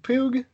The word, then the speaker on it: Pugh: No, I keep saying it wrong. I think it's Florence Pugh. Pugh.